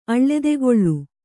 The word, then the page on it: ಅಳ್ಳೆದೆಗೊಳ್ಳು: Kannada